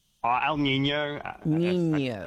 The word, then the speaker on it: El Niño: So let's make sure we're on the same page, because there's El Nino, Nino and in Niña. So what is El Nino? And am I saying it right? – El Niño.